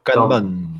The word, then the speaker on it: Kanban: Et un truc que je vais utiliser cette année, qui, alors je ne sais pas comment ça se prononce, hein, K-N-B-A-N, Kanban, je ne sais pas, Kanban. – Kanban, c'est japonais.